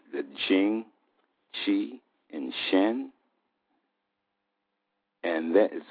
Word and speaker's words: Qi: And then we have the Qi, which is the second treasure. It is pronounced C-H-E-E, and that is translated as vitality.